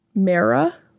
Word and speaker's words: Mara: Mara? – Yeah, M-A-R-A.